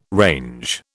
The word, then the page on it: range: British English